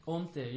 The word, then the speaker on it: hantée: Hantée, yeah. – So like a silent H of haunt? – Yeah, exactly, yeah. It's spelled H-A-N-T and then E with an accent and another E.